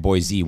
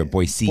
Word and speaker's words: Boise: In Boise, if you See, I even just said it wrong. So if someone's listening to this podcast, I just lost credibility. – We are not Boise. We are Boise.